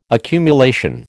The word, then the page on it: accumulation: American English